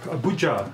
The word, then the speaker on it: Abuja: She is in, do you pronounce it Abuja? Abuja. Abuja.